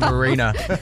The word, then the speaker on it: Arena: Lovely pronunciation of arena. – I can see you two honouring the language. Honouring the language. – It's arena. Good to hear it pronounced correctly. – Arena. – Hey? We've been saying it wrong for many years there.